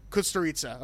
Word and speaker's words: Kusturica: Kusturica, okay.